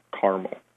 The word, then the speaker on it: caramel: So where you see red, that's where people tend to pronounce the word with two syllables, so caramel. Where you see blue, that's where people tend to pronounce the word with three syllables, or caramel.